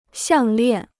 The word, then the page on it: 项链: xiàng liàn